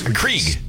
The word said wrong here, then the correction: Keurig: Krieg. – Keurig. – Keurig. Keurig. Yeah. You don't even know how to say it.